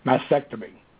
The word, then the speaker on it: Mastectomy: I want to make sure I said it right. Mastectomy. I can't remember. You guys all know. – No, that was right.